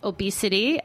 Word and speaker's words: Obey City: Um, obesity, obesity is how you spell it though. O B E Y C I T Y.